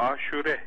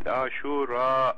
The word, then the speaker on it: Aşure: Bunun telaffuzu, doğru telaffuzu böyle. A harfi ve U harfi uzun. Aşure. Aşura diye, hatta bizim E diye okuduğumuz Aşura diye Araplarda uzun oluyor. Elif-i Memdude ile Aşura günü. Biz Aşure diyoruz kısaca.